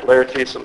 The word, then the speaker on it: Laertes: You might recall the passage in Hamlet where Polonius is talking to, is it Laertes? Is that my saying her name right? Laertes? Yeah.